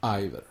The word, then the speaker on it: either: Ahora, either. También es pronunciado como either.